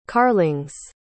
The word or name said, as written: carlings